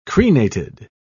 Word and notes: crenated: British English

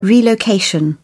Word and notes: relocation: British English

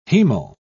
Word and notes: hemal: British English

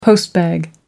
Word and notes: postbag: American English

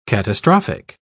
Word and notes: catastrophic: British English

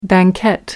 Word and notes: banquette: British English

